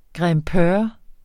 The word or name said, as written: grimpeur